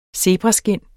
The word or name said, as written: zebraskind